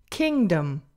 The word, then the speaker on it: kingdom: So for example, the first word is kingdom, kingdom. And it's that second syllable, the dum, dum. It's unstressed.